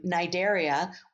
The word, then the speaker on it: Cnidaria: And actually the name Cnidaria, which has a silent C at the beginning, the Cnida part, it's Greek for stinging cell.